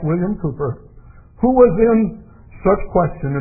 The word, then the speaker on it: Cowper: And Bill Cooper, his name is spelled C-O-W-P-E-R, but the English pronounce it Cooper, William Cooper.